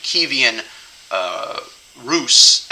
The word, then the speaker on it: Kievan Rus: And so they choose Eastern Christianity, and then the Russian people begin to be baptized, and there's famous paintings of the Kievian, in solidarity with the Ukrainians, I'm going to make sure I pronounce it correctly, by the Ukrainian pronunciation, Kievan Rus, at that time they're not even called the Russians, the Rus, who are being baptized in the river.